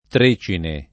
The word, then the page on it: Trecine: lingua italiana